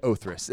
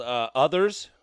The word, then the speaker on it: Othrys: We've been called Others. We've been called Othritis. We have been called all sorts of different things.